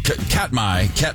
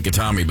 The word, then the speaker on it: Katmai: So over the summer, the brown bear out in Alaska at the Katami National Katmai. How do you say it? I always want to say Katami, but it's spelled differently.